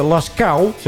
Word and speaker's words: Lascaux: En met dezelfde soort tekening op de muur, om toch mensen te kunnen laten zien wat er te zien was in die, deze Lascaux, zo moet je het uitspreken, grot.